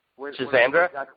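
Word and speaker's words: Schisandra: Shizrana kind of threw me off. – Shizandra? – Yeah, that's it. Pronunciation.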